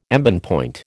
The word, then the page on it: embonpoint: American English